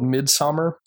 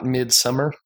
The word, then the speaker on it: Midsommar: The movie's called Midsommar. And I'm trying to pronounce that specifically because it's not Midsummer, and my accent will probably betray me on that.